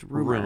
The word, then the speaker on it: RMR: So Sharon Stone, I guess, was spotted dancing with, hanging out with, canoodling with this rapper, that guy, Rumor, R-M-R. – Well, there is, I guess it's relevant that his name is Rumor. Mm-hmm. His name is R-M-R, but it's pronounced Rumor.